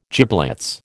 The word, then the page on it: giblets: American English